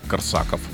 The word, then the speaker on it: Корсаков: Я сейчас хоть классно скажу ударение. Город Корсаков.